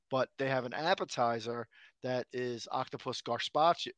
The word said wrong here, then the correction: carpaccio: I can't say it right. – Carpaccio? – Carpaccio, that's it, yeah.